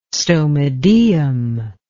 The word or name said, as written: stomodaeum